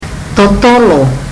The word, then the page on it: totolo: fiji